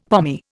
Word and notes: pommy: Indian

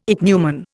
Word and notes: ichneumon: Indian

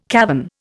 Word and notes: cavern: Indian

